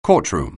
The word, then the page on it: courtroom: anglais